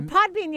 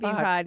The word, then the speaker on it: Podbean: Yeah, Podbean, you guys, not BeanPod. Yeah, right. Just flip it, reverse it.